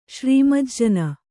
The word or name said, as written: ಶ್ರೀಮಜ್ಜನ